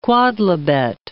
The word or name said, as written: quodlibet